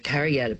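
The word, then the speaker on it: Kariyarra: the Kariyatta people, that it's spelt with a double R, but it's pronounced like duh, yeah.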